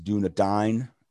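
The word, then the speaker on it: Dúnedain: Tolkien, just a correction from previous shows, pronunciation correction. It is Dunedain.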